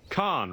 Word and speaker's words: Khan: But when I looked at the, I watched it with subtitles, and they actually spell it like K-H-N or for every other time. But when Hank says it then, he says it Khan, like James Khan, like the actor, like C-A-A-N.